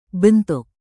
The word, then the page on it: bentuk: indonesian